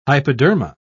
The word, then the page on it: hypoderma: British English